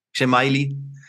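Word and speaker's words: Riola Xhemaili: Riola Schemaili, habe ich das richtig ausgesprochen? Schemaili. Ja. ja.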